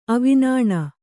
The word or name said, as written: ಅವಿನಾಣ